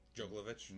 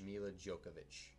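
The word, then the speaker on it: Milla Jovovich: Mila Djokovic in it. And that is how you pronounce it. – Djokovic? – Mila Djokovic.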